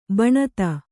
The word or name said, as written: ಭಣಿತಿ